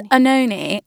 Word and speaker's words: Oenone: So it's Uenone. Now, this is, I thought, a very helpful thing to do. No one can say my name, so I was like, clever me, I'll put the phonetic spelling.